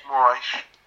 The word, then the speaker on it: Moraes: I'm sorry, I kind of pronounced the name. – Moraes. – That's how you pronounce it.